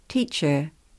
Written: teacher

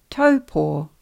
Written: taupo

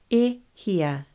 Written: E hia

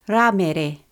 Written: Ramere

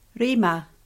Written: rima

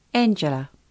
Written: angela

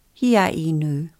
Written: hiainu

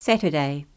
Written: Saturday

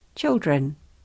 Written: children

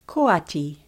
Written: Koati

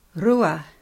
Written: rua

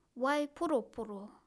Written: waiporoporo

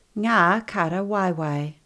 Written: Nga kara waiwai

